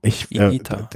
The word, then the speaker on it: Higuita: Ich weiß nicht, wie man es, Higuita, spricht man es richtig Higuita, aus? Higuita, ja. – Higuita. Also ist das U stimmlos? Higuita? – Das I, das H spricht man nicht mit Higuita.